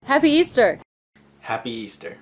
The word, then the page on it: Happy Easter: American English